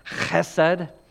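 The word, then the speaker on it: chesed (חסד): And the Hebrew pronunciation would be something like chesed with that rough.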